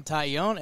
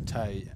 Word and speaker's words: Taillon: We actually went phonetically and were like, Taillon. – Yeah, it's like a tie and you own it. A little French.